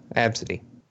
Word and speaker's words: Abcde: Yes, that's how they want it to be pronounced. Absidy.